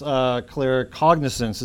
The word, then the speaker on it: claircognizance: First, it came as claircognizance. Is that how you say it? Sort of. – Yes. – As a knowing. And that's right.